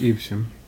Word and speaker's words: Evesham: we figured out how to say it was Evesham. – I think it's Evesham.